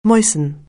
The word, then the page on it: moisten: anglais